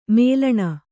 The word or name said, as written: ಮೂಲಾಣು